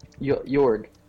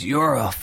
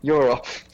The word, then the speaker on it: Yorl: I was like, okay, where can I find Yorg? – His name is Yorl. Start saying his name right. That will help. – Yorl.